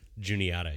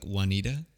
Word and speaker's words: Juniata: That's how you pronounce it? – Juniata, yeah. – It's, this entire time, I thought it was pronounced like Juanita.